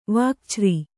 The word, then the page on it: ವಾಕ್ಛ್ರೀ: Kannada